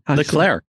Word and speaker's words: Leclerc: Leclerc. Leclerc. – Leclerc. – Leclerc. – It's like a think of it like a chocolate eclair.